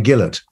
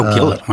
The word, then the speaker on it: Gillett: To be honest, it is actually pronounced Charlie Gillett. – Oh, Gillett. Well, but see, we're in Canada. We buy Gillette razors.